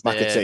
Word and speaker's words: McAtee: is it is it Mc, uh, McCarty? I can't pronounce McAtee, the Man City.